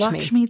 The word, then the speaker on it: Lakshmi: Lakshmi from Hindu mythology. And again, I think it's Lakshmi. – Lakshmi, thank you.